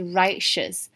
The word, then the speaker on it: righteous: 那它的形容词呢则是 righteous，它这边的 t 还是有发音，可是会跟后面一起发 shes，所以是 righteous，指的是公正的。